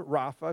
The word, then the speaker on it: Rapha: Jehovah Rapha. If you're taking notes, it's spelled R-A-P-H-A, Jehovah Rapha in Hebrew.